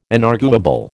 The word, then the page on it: inarguable: American English